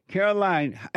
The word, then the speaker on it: Carolyn: Is it Caroline or Carolyn? – Caroline. – Caroline. And how do you spell it? – C-A-R-O-L-Y-N.